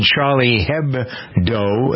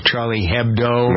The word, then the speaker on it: Charlie Hebdo: Meanwhile, over in Paris, a shootout at the French satirical magazine Charlie Hebdo. That's how it's pronounced, I believe, Richie, right? Charlie Hebdo. – That's correct.